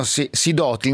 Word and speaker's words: Sidoti: Allora, Andrea Angiolino, insieme a Beniamino Sidoti, non so l'accento dove va messo, è giusto? – Sidoti è perfetto.